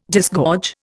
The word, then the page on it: disgorge: Indian